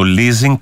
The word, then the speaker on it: leasing: Ο Δημήτρης λέει, επιτέλους σταματήστε να λέτε το leasing. Leasing. Διαβάζεται σε όλο τον κόσμο.